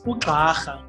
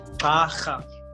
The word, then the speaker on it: Ugxarha: So it is Ugxarha, right? So Ugxarha, okay. You know, like the same way you say Gxabashe, you know, yeah. It's Ugxarha. Yeah.